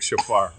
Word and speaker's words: shofar: So, because I looked down at his travel shofar. Shofar, is that how you say it? – Yeah, shofar.